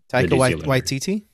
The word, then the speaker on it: Taika Waititi: What I was surprised by with this, because Thor Ragnarok, I mean, I like, I'm going to mispronounce his name, Takiti, the director. – Taika Waititi?